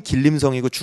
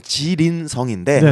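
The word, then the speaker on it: 지린성 (吉林省): 길림성. 한국어 네. 발음이 길림성이고 중국어 발음 지린성인데 네네.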